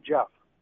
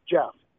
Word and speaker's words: Jeff: Jeff. – Jock? – Jeff. – How do I spell it? – J-E-F-F. – J-E-F-F.